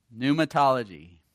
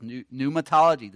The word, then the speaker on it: Pneumatology: Pneumatology. Silent P. Danny knows that because he's a construction man. He has pneumatic drills. P-N-E-U-M-A. Pneumatic. Pneumatic. P is silent. Pneumatic drills. Pneumatology.